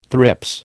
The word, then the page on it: thrips: American English